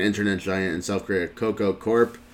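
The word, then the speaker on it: Kakao: Cocoa or cacao, or it's kind of like spelled like cocoa, like the chocolate bean, like cocoa beans, but it's with a K. K-A-K-A-O.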